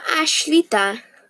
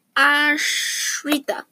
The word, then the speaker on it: Ashrita: It means dependent or Ashlita. Not Ashlita. Ashrita. I think that's how you pronounce it.